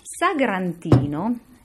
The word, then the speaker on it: Sagrantino: Sagrantino is the name of a grape and the red wine made from it, primarily around the town of Montefalco.